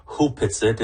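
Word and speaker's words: Opitsaht: It's written Opitsit, but it's Opitsit. Actually pronounced properly Hupitsit, and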